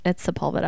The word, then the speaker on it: Sepulveda: I said Sepulveda like three times. And one of the girls I work with, Lauren, she's like, it's Sepulveda. I'm like, I'm sorry. I know it is. I'm so sorry. I just said it wrong.